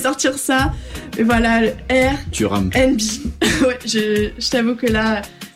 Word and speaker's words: R&B: Le R&B. – À savoir dans le contexte quand même, parce que j'ai mal prononcé tout à l'heure. Je dis Airbnb, l'erreur totale.